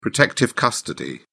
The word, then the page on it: protective custody: British English